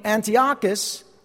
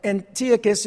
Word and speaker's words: Antiochus: And there was a man by the name of Antiochus, or Antiochus, if you like to pronounce it that way, the fourth.